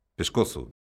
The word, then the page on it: pescozo: galega